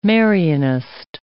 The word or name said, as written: Marianist